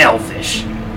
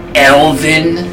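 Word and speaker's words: elven: Okay, the last time I said elfish, this high and mighty elf was like, "Excuse me, it's pronounced Elvin."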